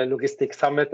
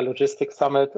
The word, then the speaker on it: Logistics Summit: Der eine sagt äh, Logistik Summit, der andere sagt Logistics Summit. Äh, äh, Thomas, der es äh, erdacht hat, sagt immer Logistics Summit und ähm, möchte das also eigentlich international ausgesprochen haben. Ja. Du kannst es nennen, wie du willst.